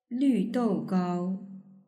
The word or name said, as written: lvdougao